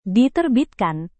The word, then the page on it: diterbitkan: Indonesian